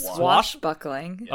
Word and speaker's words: swashbuckling: The Princess Bride is a deft, intelligent mix of a sosh buckling romance and did I say that right? Sosh buckling? – I think it's swash buckling.